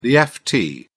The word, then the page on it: ft: British English